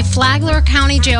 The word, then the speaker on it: Flagler County: This is, I don't know if I'm going to say this right, Flagler County? – Yeah, Flagler County, yeah. Okay. You got it.